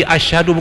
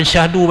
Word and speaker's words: asyhadu: Karena kita tegur, kita perbaiki asyhadu bukan syahadu bacanya.